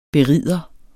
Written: berider

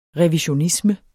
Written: REVISIONISME

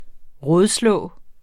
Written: rådslå

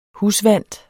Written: husvant

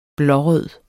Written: blårød

